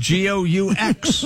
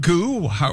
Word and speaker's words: Goux: We're spelling goo, G-O-U-X. – Is that how you pronounce that? – I would think goo or go-ux. It's not go-ux, I can tell you that.